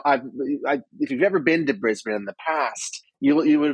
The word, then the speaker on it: Brisbane: So it's doing all these preparations, and that is the city of Brisbane, which some people mispronounce as Brisbane because that's how it looks. But it's Brisbane, and it's on the east coast of Australia in Queensland, sort of like in the middle of the coast.